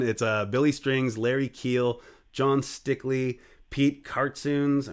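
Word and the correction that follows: Kartsounes: I'm mispronouncing that, I'm sure, Tony Camel, and... – Yes, you are. It's Cartsoonist.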